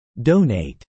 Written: donate

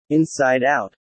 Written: inside out

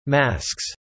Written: masks

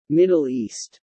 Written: Middle East

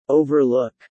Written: overlook

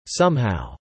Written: somehow

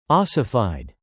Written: ossified

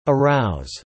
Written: arouse

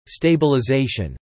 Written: stabilization

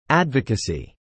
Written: advocacy

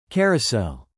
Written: carousel

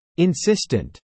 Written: insistent

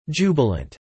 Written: jubilant

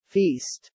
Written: Feast

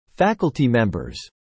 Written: faculty members

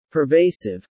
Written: pervasive

